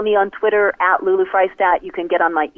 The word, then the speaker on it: Friesdat: Her last name is spelled Freistadt, like French fries, F-R-I-E-S. D-A-T, Lulu Freistadt.